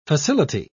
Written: facility

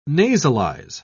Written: nasalize

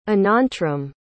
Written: anantram

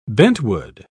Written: bentwood

